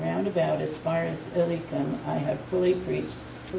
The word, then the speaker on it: Illyricum: Yeah, Illyricum, Illyria. I only know how to pronounce that because it's in Shakespeare plays. And Krista taught me how to pronounce it.